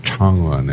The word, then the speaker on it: Changwon: But the official name of the city... – Changwon. – Is Changwon, yeah.